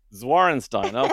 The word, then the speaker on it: Zwarensteyn: We are now joined by, I'm going to try and pronounce this, hopefully I'll get it right, Jill Zwarenstein. Am I close, Jill? – You're close. It's Zwarenstein. – Zwarenstein. Okay, I had to have a go at it first.